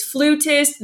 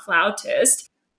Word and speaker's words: flutist: It is flutist, not flautist.